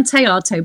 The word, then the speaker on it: Théato: It's Anne Theato, but very well done so far.